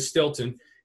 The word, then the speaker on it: Steelton: It's spelled Steelton, Pennsylvania, Stilton.